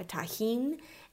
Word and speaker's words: Tajín: there is the seasoning that is spelled T A J I N, uh, Tajín, and that is very similar.